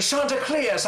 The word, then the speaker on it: Chanticleer: Their offense, the Shanta Chanticleer. She got to pronounce it very distinguished, the Chanticleer, I do declare.